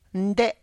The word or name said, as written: nde